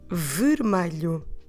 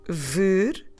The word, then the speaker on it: vermelho: Vermelho, three syllables, and the spelling is V E R M E L H O Vermelho.